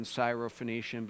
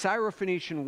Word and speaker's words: Syrophoenician: David was a little bit worried about his pronunciation on Syrophoenician, but he did an excellent job. But this Syrophoenician woman, a woman who is a Gentile, or as Matthew says, a Canaanite.